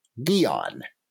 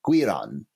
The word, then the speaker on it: Giron: Alternately, you could say, well, a lot of Americans pronounce that word guillotine, in which case you want to call this monster Gillon. Now, those are both totally defensible ways of translating the monster's name. Neither of them hews particularly closely to Giron.